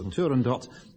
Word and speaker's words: Turandot: I like to pronounce the final T and the pronunciation department of the BBC permit me to, so Turandot in Puccini's opera.